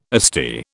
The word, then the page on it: Asti: British English